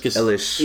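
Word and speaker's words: Eilish: Billy, how do you say her last name? Ilish? – Ilish.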